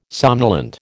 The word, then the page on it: somnolent: American English